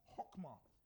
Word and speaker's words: chokmah: The word wisdom in the Hebrew is chokmah, pronounced with a CH, but said as chokmah.